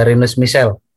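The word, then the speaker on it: Rinus Michels: beruntung dia itu kan ada Rinus Michael ya Rinus Michel gimana sih bacanya Ya, Rinus Michel.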